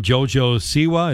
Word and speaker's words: JoJo Siwa: Carmen Electra, Ice-T, Steve-O, and JoJo Siwa.